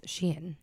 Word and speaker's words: Shein: It is Shein.